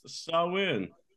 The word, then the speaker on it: Samhain: And although it is spelled differently, it is pronounced Samhain.